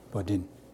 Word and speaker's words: Bourdain: Bourdain.